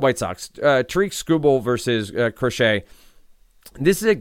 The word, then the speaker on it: Crochet: But anyway, it is Crochet. So Crochet. Garrett Crochet.